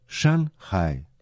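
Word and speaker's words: Шанхай (上海): По-китайски его название звучит как Шанхай. Обратите внимание на то, что первый иероглиф «шан» произносится в четвертом тоне, а иероглиф «хай» – в третьем.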